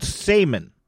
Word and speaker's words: saimin: The state dish of Hawaii is called salmon, and I'm going to spell it. S-A-I-M-I-N.